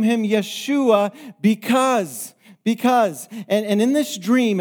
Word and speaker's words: Yeshua: He didn't hear the Greek word because that's the transliteration of the Hebrew word that comes from the word Joshua that's pronounced Yeshua.